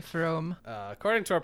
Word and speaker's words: Frome: So we got this first one, which uh, for our dear listeners is F R O M E, which I would assume is pronounced like from, like Jake Fromm, the quarterback.